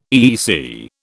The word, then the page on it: EEC: British English